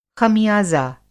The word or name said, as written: ख़मियाज़ा